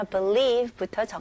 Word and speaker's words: believe: 강세가 어디에 들어갔어요? 네, 첫 번째 음절보다는 두 번째 음절에 들어가죠. Believe.